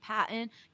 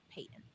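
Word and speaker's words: Paton: And actually, for anyone listening, that is how you pronounce it. I know a lot of people thought it's Patton because it's P-A-T-O-N, or there's a bunch of different pronunciations, but it is George Payton.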